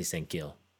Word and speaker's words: Sang-gil: Which I always thought was kill, K-I-L, mm. But as we all have now learned that the K's and the G's are very similar, so yeah, so I'm pronouncing it more the gil, I guess. Lee Sang-gil.